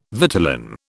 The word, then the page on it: vitellin: British English